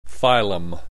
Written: phylum

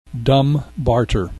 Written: dumb barter

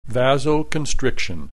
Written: vasoconstriction

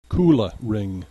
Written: Kula Ring

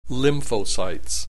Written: lymphocytes

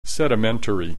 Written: sedimentary

